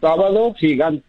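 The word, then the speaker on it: Sábado Gigante: Sabato Gigante.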